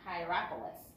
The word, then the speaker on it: Hierapolis: Laodicea and Hierapolis.